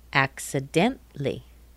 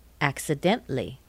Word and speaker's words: accidentally: Accidentally. Accidentally. What did you take out? What can't you hear? That A in T-A-L-L-Y. In American English, it's pronounced tli. Accidentally. Accidentally. Basically. Basically. C-A-L-L-Y, that A is not there. Basically. Anytime you see C-A-L-L-Y in American English, you'll pronounce it cle.